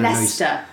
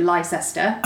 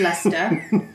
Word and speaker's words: Leicester: Leicester. I think you Americans mispronounce it by calling it Leicester. – Okay, gotcha. – So I'm here to correct you. It's Leicester. please don't do that again.